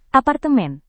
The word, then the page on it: apartemen: indonesian